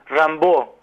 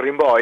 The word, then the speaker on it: Rimbaud: Los que saben francés le dicen Rimbaud, pero nosotros siempre le dijimos Rimbaud y sí. No creemos mucho cuando lo pronunciamos bien como correspondería. Así que vamos a decirle Rimbaud, que todo el mundo entiende más o menos de quién estamos hablando, ¿no? – Sí, señor.